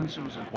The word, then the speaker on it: OpenSUSE: Yeah, OpenSUSE.